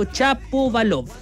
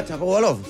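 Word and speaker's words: Shapovalov: que es en este caso Chapovalov. – Ah, Chapovalov, sí.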